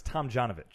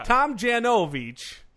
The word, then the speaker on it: Tomjanovich: I believe it's Tomjanovich. – Tomjanovich. – I believe don't correct him. Oh, yeah. He knows how it's pronounced. – Okay, sorry. – I do. – Yeah. – Tomjanovich.